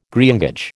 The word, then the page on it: greengage: American English